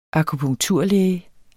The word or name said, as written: akupunkturlæge